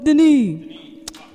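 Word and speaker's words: Denis: But it's spelled S-O-N-I-A-D-E-N-I-S. Most people say Sonia Dennis. So, like, whenever I do a show, I always get, like, an introduction, like, come on your stage, Sonia Dennis.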